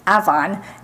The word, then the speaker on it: Avon: It's not Avon. It's Avon, New York, which is outside of Rochester.